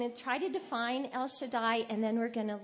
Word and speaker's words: Shaddai: Shaddai is the Hebrew word, and it's pronounced Shaddai in Hebrew.